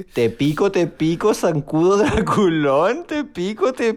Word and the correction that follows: picó: Te picó, niña, es con acento en la O.